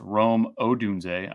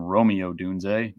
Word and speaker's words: Rome Odunze: I'm going to go with Rome Odunze and that's Rome Odunze. I went over my frustration with the pronunciation previously. Chris Sims from NBC Sports kept calling him Romeo Odunze, not close.